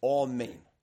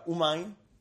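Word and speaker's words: amen (אמן): So the actual correct pronunciation of the word amen is amen. Okay? Amen. Okay? It's true, the Hasidim say umayn.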